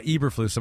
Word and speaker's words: Eberflus: Now, Eberflus, am I saying that right? – Yeah, even? – You are. Yeah.